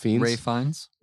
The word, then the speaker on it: Fiennes: Ray Fiennes. – Yeah, I always get mixed up. – I say Ralph Fiennes too. – Okay, he's posh, so you don't pronounce the L-P-H.